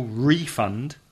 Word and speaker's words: refund: The stress is on the first syllable, refund, to ask for a refund. And if it's a verb, the spelling is the same, but change the stress to the second syllable.